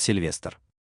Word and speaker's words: Сильвестр: Меня зовут Сильвестр». Ударение на последний слог, приподнявшись, сказал чародей.